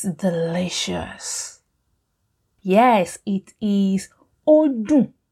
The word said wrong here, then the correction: oladun: Odun. Er, oladun. Oladun. well done if you remember that.